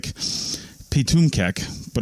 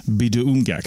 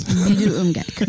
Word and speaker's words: Pituamkek: It looks like Pitumkek, but it's pronounced Biduumgek. – Biduumgek. Yeah. – Yeah. – Yep. – Yeah. So Pituamkek mm-hmm. is pronounced Biduumgek.